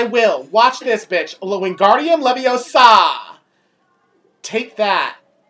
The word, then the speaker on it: Leviosa: It's Leviosa. – Accent on the saw, you fuck.